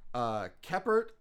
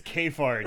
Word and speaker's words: Kephart: It's written by Patricia Gomez and Elsa Keppert, or I could pronounce it, could be also Kefart. – Kefart? – Her name is spelled K-E-P-H-A-R-T. – Oh, it's Kefart. – It's Kefart. – Kefart. Kefart. Yeah. – That's great. – Also directed by Elsa Kefart. Keppert. It's Kefart. We're saying Kefart.